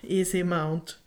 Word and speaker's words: Easy Mount: Men man måste köpa något som kallas för, jag vet inte hur det uttalas, EZ Mount. – Mm. Jag tror inte det ska vara Easy Mount ja, som är easy. easy. Easy Mount.